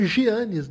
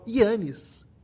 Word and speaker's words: Giannis: É muito louco isso também, Lucas, porque no grego eles não falam Giannis, né? Como se escreve, eles falam Iannis É, como eu tento chamá-lo em forma de respeito.